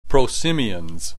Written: prosimians

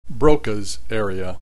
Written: Broca's area

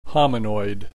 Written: hominoid

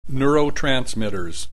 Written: neurotransmitters

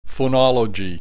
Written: phonology

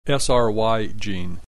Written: SRY gene

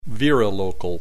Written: virilocal

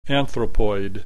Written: anthropoid

